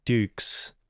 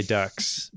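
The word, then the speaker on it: Dux: Well, I also got distracted by the background of Frank Dukes. I keep trying to say ducks because it's spelled ducks.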